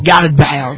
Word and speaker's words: Gareth Bale: Y excelente pronunciación, así muy, claro, muy Luis, de Gales, muy Luis de Cardo. – Sí, votó el acento nativo. – Gareth Bale.